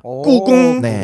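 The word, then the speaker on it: 꾸궁 (故宫): 그러니까 꾸궁이라고 합니다. – 꾸궁.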